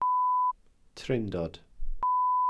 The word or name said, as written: trindod